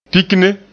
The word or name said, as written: pikne